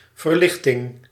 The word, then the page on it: verlichting: /vǝɾ.lɪχ.tɪŋ/